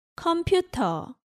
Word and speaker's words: 컴퓨터: 컴퓨터. – Again, there is no R sound in Korean, so in Korean you say 컴퓨터 without the R at the end.